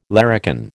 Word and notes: larrikin: American English

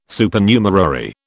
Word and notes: Supernumerary: British English

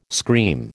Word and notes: scream: American English

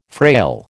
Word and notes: frail: American English

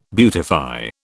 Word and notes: beautify: British English

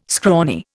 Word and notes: scrawny: Indian

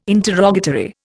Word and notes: interrogatory: Indian

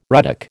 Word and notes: ruddock: American English

